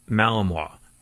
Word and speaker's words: Malinois: A Belgian Malinois.